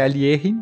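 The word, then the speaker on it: Alekhine: falar o nome dele corretamente, é Alierin, né, a pronúncia russa.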